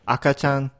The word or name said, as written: akachan